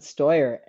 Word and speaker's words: Steuer: Yeah, you can pronounce it Steuer.